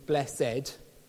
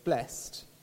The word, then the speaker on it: blessed: At least not in its archaic form of two syllables with an accent over the second E, blessed. It has resonances of Shakespeare and the King James Bible. And its modern pronunciation of blessed